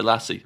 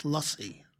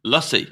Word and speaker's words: lassi: It's not lassi. It's not the dog. – What? – Although it's spelled mangoes. – Yes, I know it's spelt like the dog, but it's a sound. It's lussy. – Lussy. – Like a U.